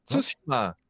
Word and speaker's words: Tsushima: Tsushima. – Tsushima. – Tsushima. – Klemtoon, eerste.